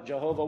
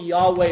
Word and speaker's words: Yahweh: Jehovah works better in some of our Psalms when we need an extra syllable, but probably Yahweh is closer than is Jehovah.